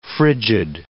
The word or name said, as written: frigid